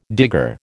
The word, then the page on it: digger: American English